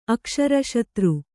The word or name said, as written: ಅಕ್ಷರಶತ್ರು